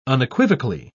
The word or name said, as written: unequivocally